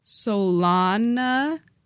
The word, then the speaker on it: Solanaceae: The A-E is pronounced like I, like your I. – So say it again slowly. – Solanaceae.